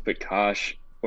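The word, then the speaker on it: Pekař: There we have Oskari Laaksonen, Matei Pekar, Pekash, possibly. – I guess if we go the Czech pronunciation is Pekash. Or Picard. I guess you go either way.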